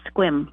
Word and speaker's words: Sequim: Yes, it's spelled S-E-Q-U-I-M, like Sequim. Pronounced Squim.